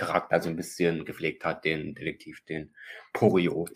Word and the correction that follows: Poirot: den er da spielt. Wenn genau. ich den jetzt richtig ausgesprochen habe. – Ja, Hercule Poirot heißt er, glaube ich. Hercule Poirot, ah, da habe Poirot. Poirot.